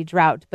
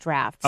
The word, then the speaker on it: draught: So here, D-R-A-U-G-H-T would be drought, but there it's pronounced draft.